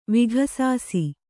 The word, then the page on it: ವಿಘಸಾಸಿ: vighasāsi